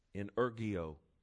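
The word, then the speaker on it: In ergio (ἐνεργέω): In ergio is how you pronounce it.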